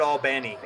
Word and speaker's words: Albany: They pronounce it Albany. Albany.